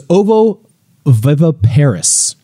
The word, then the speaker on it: ovoviviparous: So moving on, Marin Jalitza from Instagram is curious to know, Shana, were some dinosaurs ovoviviparous, which is a word that is very hard for me to say.